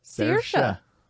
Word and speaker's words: Saoirse: Everybody says her name wrong, too. – What is it? – Saoirse. – And what does everybody else say? – Saoirse. – Yeah. – Saoirse. Sorcy. – Scorsese.